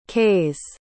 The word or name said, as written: kays